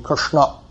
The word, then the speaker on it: Krishna: And most of us don't pronounce Krishna properly. It should be Krishna.